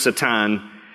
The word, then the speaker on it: Satan: And Satan, the name Satan, is derived from the Hebrew, spelled the same way, pronounced Satan, and his name just simply means enemy or adversary.